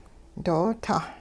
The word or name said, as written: doo-tah